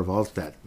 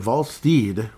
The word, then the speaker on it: Wallstedt: And I heard another pronunciation of Jesper Wallstedt. It's not Stedt. It's like, I forgot what it is. Like Steed, I think. Jesper Wallstedt. Wrap your head around that. Jesper Wallstedt. So anyhow, Mr. Wallstedt with 2.09 goals against average.